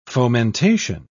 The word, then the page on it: fomentation: British English